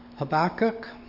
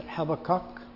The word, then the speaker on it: Habakkuk: Our prayer time, I'll be taking it from Habakkuk, or some pronounce it Habakkuk, more in the English tradition. We have a tendency to emphasize the first section of a word. The Hebrew language tends to emphasize the last section of the word, so that's where we get those pronunciation differences.